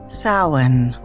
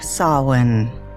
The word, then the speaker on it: Samhain: Another curiously spelled word with a very non-intuitive pronunciation. The word is spelled Samhain. It is Gaelic in origin and pronounced Samhain or Samhain, and simply means summer's end.